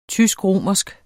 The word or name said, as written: tysk-romersk